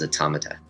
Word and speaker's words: automata: Putting an S at the end is not the proper way to say it. Proper way to say it is automata.